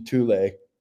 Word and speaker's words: Thule: It depends if you're saying it in German, English, or Norwegian. – Gotcha. – I prefer the Norwegian pronunciation, Thule. Thule, though. Thule, yeah. Just like the roof rack by Thule, the bike racks.